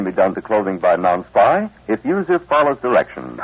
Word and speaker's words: Nonspi: pronounce N-O-N-S-P-I? – Non-spy?